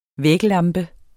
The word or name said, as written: væglampe